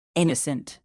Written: innocent

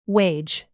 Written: wage

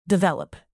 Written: develop

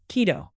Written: quito

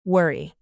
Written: worry

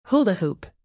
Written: hula hoop

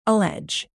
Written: allege

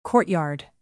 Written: courtyard

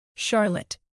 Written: charlotte

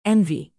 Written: envy

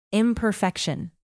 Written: imperfection

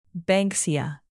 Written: banksia

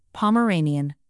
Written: pomeranian

